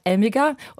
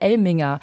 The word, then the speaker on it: Elmiger: Wiebke, du hast gerade gesagt Elmiger und meiner Ansicht nach ist das auch die richtige Aussprache dieses Namens, aber man hört auch immer wieder Elminger.